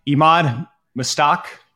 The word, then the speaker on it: Emad Mostaque: All right, Emad mostaque, did I say that right? – Yep.